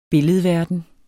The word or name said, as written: billedverden